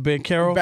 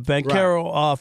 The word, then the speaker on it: Banchero: Okay, Banchero, is that it? How you say it? Banchero? – Banchero.